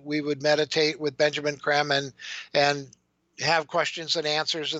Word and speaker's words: Creme: His name is spelled C-R-E-M-E, okay. Benjamin Krem. It looks like cream, but it's not.